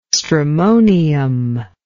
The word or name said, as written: stramonium